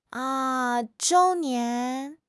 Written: 周年

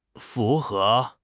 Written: fu2 he2

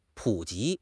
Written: 普及

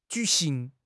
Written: ju3 xing2

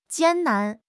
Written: 艰难

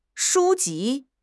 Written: 书籍

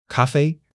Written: ka1 fei1

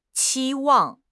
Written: 期望

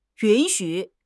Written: yun3 xu3

